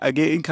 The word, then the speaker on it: Algae Inc.: Und zwar freuen wir uns, Basti und ich, dass Julia und Christoph da sind, die Autoren von Algae Inc. Algae Inc., habe ich das richtig ausgesprochen? – Klingt sehr gut, das darfst du machen, wie du.